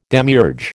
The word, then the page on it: demiurge: American English